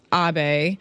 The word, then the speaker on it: Abe: Before we get too far off the topic, first I want to apologize for mispronouncing the former Japanese PM's name. It is pronounced Abe, Prime Minister Shinzo Abe, I believe.